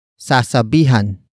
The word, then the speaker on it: sasabihan: Word for word, it's sasabihan ko ang super na pumara.